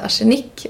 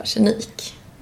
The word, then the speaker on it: arsenik: Jag fick ju en tillsägelse av min sambo i år att det heter inte arsenik, utan det heter arsenik. – Så jag kommer säga arsenik, men Mova har fått fritt kort att säga arsenik om hon vill.